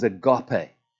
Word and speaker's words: agape: God's love is agape. How do you say it? Agape? Agape. All of the above.